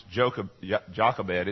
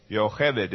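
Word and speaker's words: Yo hebed (יוכבד): But her name especially is not pronounced Jochebed. It's pronounced, well, let me, first of all, let's replace the J with a Y. So the first part is yo. Can you repeat after me? Yo. The second syllable is k. Yo k. Yo k. And the last syllable is bed. Yo kebed. Yo hebed is the way you pronounce it in Hebrew.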